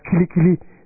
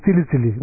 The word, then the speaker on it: kili kili: You're supposed to pronounce kili kili. He says, he's saying tili tili.